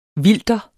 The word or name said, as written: vilter